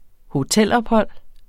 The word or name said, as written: hotelophold